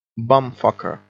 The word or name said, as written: bum fucker